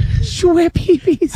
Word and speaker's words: Schweppes: Schweppes. – Isn't that how you pronounce it? – Schweppes.